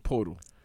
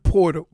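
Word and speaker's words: Pöltl: And as of right now, RJ Barrett and Jacob Poyatil. How do you say this guy's name? – Portal. – Jakob Portal. Jakob.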